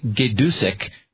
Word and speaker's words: Gaydusek: I believe Jim's last name is pronounced Gaydusek, G-A-Y-D-U-S-E-K.